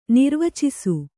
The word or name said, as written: ನಿರ್ವಾಚಿಸು